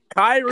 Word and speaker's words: Kairi: Like Bretsky said earlier, she got Kairi. the sheet metal. – Kairi. – Kairi, you stupid bitch. We corrected you earlier and you still said it wrong.